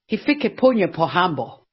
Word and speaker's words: Hifikepunye Pohamba: Hifikepunye Pohamba.